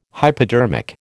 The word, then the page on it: hypodermic: American English